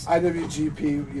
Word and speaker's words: IWGP: We had another... IGP United States title defense. – IWGP United States.